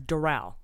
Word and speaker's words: Doral: The pronunciation of Trump's golf club is Doral.